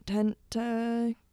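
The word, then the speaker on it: Tentacion: We've got number 5, question mark, from XXX Tentication. That's awkward. – Tentation? – Tentation. You're Tentation. – Right. – Tentation. Learn to pronounce things, Shannon.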